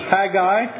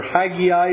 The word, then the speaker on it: Haggai: Chapter 1:1 of Haggai, or Haggai if you prefer. We won't fall out about pronunciation.